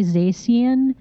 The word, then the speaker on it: Zacian: Zacian, but I say Zacian. – Zacian. Yeah. Okay. – It's supposed to be Zacian.